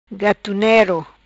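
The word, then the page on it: Gatunero: patua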